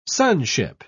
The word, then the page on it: sonship: British English